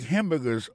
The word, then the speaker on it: hamburger: N'a pas remarqué que dans mot hamburger, ça, comme t'as dit, il y a trois syllabes là-dedans, côté appuyé, puis plus ça, c'est sous premier syllabe là.